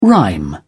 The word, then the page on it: rhyme: British English